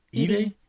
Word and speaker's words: Eady: Is it Edie? Edie, right? – E-A-D-Y?